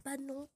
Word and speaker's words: Padnon: Enfin, le Padnon Podcast, c'est comme ça que ça se prononce.